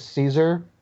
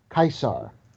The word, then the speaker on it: Caesar: Like, you know how everyone calls the guy Julius Caesar? – Yeah. – Yeah. – That's not how you pronounce it. It's actually Julius Caesar.